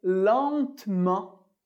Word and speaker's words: lentement: Dessa palavrinha a gente forma outra, que é lentement. Lentement, que significa lentamente. E aqui a gente tem duas vezes o som un.